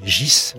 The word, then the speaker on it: Ghys: Gys, ça s'écrit G-H-Y-S, mais ça se dit Gys.